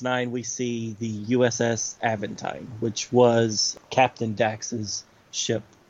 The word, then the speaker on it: Aventine: Is that how you pronounce it? Evelyn? – No. – It's like, once it came out of my mouth, I was like, that's not right. Aventine. – Aventine, yes.